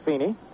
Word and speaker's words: Fiene: F-I-E-N-E, unusual spelling, but it's pronounced Feeney.